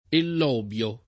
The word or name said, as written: ellobio